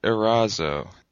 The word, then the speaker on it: Erazo: And finally, last but not least, we have Matt Erazzo.